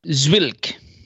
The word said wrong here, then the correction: Zwickel: Zwickel. – Sorry, I wasn't sure how to pronounce the L in that situation.